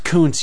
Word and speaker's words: Koontz: Dean Koontz once said, it's pronounced Koontz, you jerks.